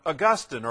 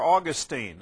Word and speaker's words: Augustine: And later, in a few moments, we're going to talk about Augustine or Augustine. Doesn't make any difference how you pronounce his name one way or the other. If you want to sound more academic, it's Augustine. If you want to be basic, it's Augustine. But they're the same fella.